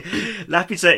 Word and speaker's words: Laputa: If you pronounce it that way, it's fine. – Yeah. – Just don't put the accent on the U. – Yeah, so Laputa is this...